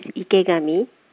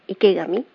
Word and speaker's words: Ikegami: My first name is Aiko, Aiko, and the last name is Ikegami, Ikegami. It is Japanese name.